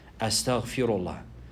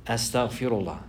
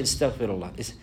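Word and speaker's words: Astaghfirullah (أستغفر الله): Astaghfirullah. Astaghfirullah. He has to learn how to say it properly likewise. Some people, they say istaghfirullah, istaghfirullah. And they'll make the harakat wrong.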